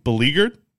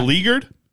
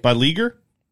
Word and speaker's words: beleaguered: I don't think I said it right the first time. Beleaguered? – Yeah. – Beleaguered? – Sounds right. – By Leaguer? – I think you had it the first time.